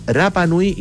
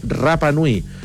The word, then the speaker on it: Rapa Nui: Muchos se preguntarán por qué digo Rapa Nui y no Rapa Nui. Porque en contacto en alguna vez de la vida con auditores de Isla de Pascua, de Rapa Nui, decían que no se dice Rapa Nui, es Rapa Nui. Lo mismo que algunos dicen Hangaroa, es Angaroa. La R no se pronuncia como una R, sino que como una R.